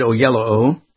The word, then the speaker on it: Oyelowo: And our buddy David Oyelowo, right? – Yeah, and we all we've talked about how to pronounce that name. – It's exactly. – Like yellow.